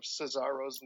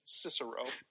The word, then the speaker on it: Cesaro: People have corrected him on his pronunciation of Cesaro's name for that entire period of time. – Yeah. – And yet he still refers to the man as Cicero.